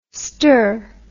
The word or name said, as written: ster